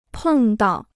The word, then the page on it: 碰到: pèng dào